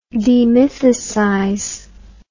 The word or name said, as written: demythicization